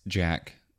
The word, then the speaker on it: Jak: I mean, I think I had a connection with this game at the very beginning because Jak, even though it's spelled with no C, I was still like, it's Jak, you know what I mean?